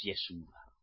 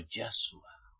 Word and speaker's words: Yahshua: Le llamáis Yeshua, los latinos, los de habla hispana. Y no es Yeshua, sino Yahshua.